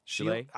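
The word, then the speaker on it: Sheele: Shield. – I've been saying Shield, but I could Shield. – Be wrong. – Shield from Akame Ga Kill. – You said it right.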